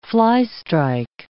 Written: fly-strike

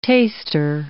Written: taster

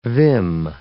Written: vim

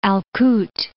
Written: Al Kut

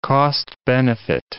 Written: cost-benefit

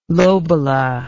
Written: lobolo

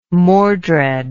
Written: mordred

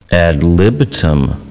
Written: ad libitum

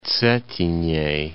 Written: Cetinje

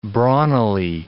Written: brawnily

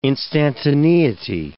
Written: instantaneity

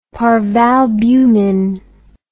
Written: parvalbumin

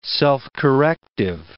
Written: self-corrective

